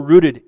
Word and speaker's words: rooted: He says, you were rooted in him. I even said it right the Pennsylvania way. Rooted. Like root beer.